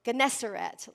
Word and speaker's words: Gennesaret: I always say it wrong. Let me look for it really quick. Gennesaret, Lake Gennesaret.